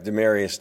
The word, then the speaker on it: Demaryius: But if people want to know his name does not look like it's pronounced. It's Demaryius, or Demaryius, sorry.